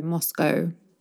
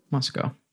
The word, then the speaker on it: Moscow: Yeah, because like that O-W in English, we could say, well, probably for you as well, we would say ow or O. And in British English, we pronounce it as O, Moscow. – Moscow.